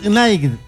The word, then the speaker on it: Knight: Gladys Knight. K. Knight. Gladys K. Knight. Así se escribe. K. Knight. Busca la canción, claro. N-I-G-H-T.